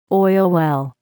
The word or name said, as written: oil well